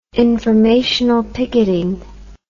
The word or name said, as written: informational picketing